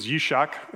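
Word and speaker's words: yishak (יצחק): It means yishak is how it's said in Hebrew.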